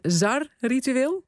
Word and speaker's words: zar-ritueel: Wat is een, spreek ik het eigenlijk überhaupt goed uit, een zar-ritueel? – Volgens mij spreek je het helemaal goed uit.